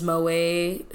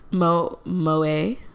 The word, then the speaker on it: Moët: Moët, like Moët Champagne. Moët, but it's like Moët. Isn't that how it's said? – I think you're pronouncing it French. – Right. – But you're in America.